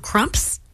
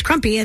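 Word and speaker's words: Krumpe's: And I think it's just pronounced Crumps. There's Crumps Donuts, K-R-U-M-P-E. – Okay. – S. So I don't think it's Crumpy. I think it's Crumps Donuts.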